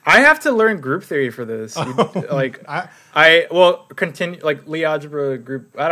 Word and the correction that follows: Lie: It's pronounced Lee. See, that's how disconnected I am from Matt these days. I didn't even know it was pronounced Lee. I thought it was pronounced Lie.